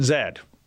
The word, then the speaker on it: Z: I know, but we pronounce it Zed. – Even if it says Z-E-E? – That's my rule.